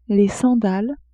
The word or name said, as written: sandales